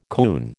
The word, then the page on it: coon: American English